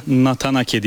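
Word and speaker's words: Nathan Aké: Nasıl telaffuz edildiğinin bir önemi yok ama Hollanda'da Nathan Ake diye telaffuz ediliyor. Bu şekilde söyleyebilirsiniz.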